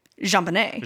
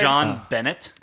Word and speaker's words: JonBenet: And so therefore, I can only say it like JonBenet. – JonBenet. – Yeah. Oh, yeah. – So that's how she'll be referred to this whole podcast. – Is it actually, can we switch it? – No, it's one word. – And do John Bennett? – John Bennett. No, because that's the father.